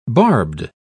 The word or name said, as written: barbed